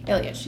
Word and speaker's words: Ilyich: Ilyich, yes.